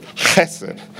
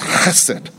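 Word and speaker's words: chesed (חסד): You have to say it like this, chesed. If you don't cough up a lung or spit on somebody, the rabbi is going to get mad at you. It's chesed. In fact, it's indescribable.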